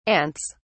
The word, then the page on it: ants: British English